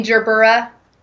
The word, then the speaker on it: Gerbera: Oh my gosh, I bought some Gerber daisies the other day. – You mean Gerbera? – Is that really how they're pronounced? – No. – Oh. No. I want to call them that now. Gerbera.